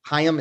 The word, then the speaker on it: Haim: And it's a weird thing because I actually think this actress, what Alana Haim, is that, or Haim, is that how you pronounce it? – Yeah.